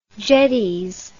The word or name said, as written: jetties